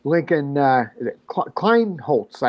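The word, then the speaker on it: Lincoln Kienholz: We've got the youngster, Lincoln Kleinholz, I believe is how we pronounce that, correct? – Yeah.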